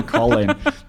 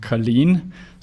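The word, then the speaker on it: Călin: It's actually Colin, because my name is Romanian. So it has like a C-A-L-I-N. And on top of the A, you have like this sort of a hat, and it's pronounced Colin.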